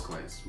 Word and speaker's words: Clent: Facebook, just Clint Holmes, C-L-E-N-T. I say that because my name is spelled different than most Clints.